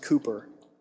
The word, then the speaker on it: Cowper: His last name is spelled C-O-W-P-E-R, but it was actually pronounced Cooper.